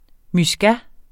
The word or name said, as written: muscat